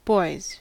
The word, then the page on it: boys: American English